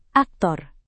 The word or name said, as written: aktor